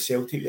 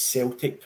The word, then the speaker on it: Celtic: But the first thing we were going to talk about tonight is, why is Celtic pronounced with a soft C instead of a hard C, as in Celtic jewellery or Celtic artwork or Celtic music?